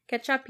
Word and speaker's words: Quechua: Quechuan, I think I said it right. Quechuan people. – I think it's just Quechua. – Quechua? Like, yeah, I don't think you say the U or the E.